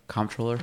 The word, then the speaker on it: comptroller: So what about, so we have comptroller, we have, am I pronouncing that right? Comptroller? – Yeah, it's spelled... – has a P in there.